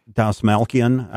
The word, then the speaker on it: Dastmalchian: I'm always going to mispronounce his name. David Desmalchian. Is that how you say his name? – Dasmalkian. – I always mispronounce it.